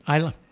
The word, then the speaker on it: Isla: Isla.